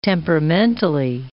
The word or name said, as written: temperamentally